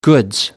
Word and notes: goods: British English

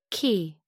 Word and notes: qi: American English

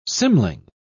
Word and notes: cymling: English; British English

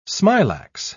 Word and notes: smilax: British English

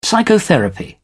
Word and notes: psychotherapy: British English

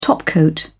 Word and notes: topcoat: British English

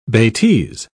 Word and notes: betise: British English

